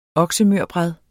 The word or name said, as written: oksemørbrad